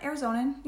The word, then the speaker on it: Arizonan: So, you're a native Arizonan. Arizonian? Yep. How do you say it? – Yeah, Arizonan. Yeah.